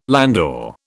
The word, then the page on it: landau: British English